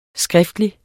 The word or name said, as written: skriftlig